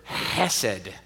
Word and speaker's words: hesed: It's the Hebrew word hesed. Hesed, and you kind of have to do that little like grovelly, loogie creating sound at the beginning to make it sound legit.